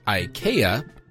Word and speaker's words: IKEA: IKEA, because that's how you pronounce it, took advertising in a whole new direction with its print ad for a crib.